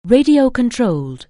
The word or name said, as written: radio-controlled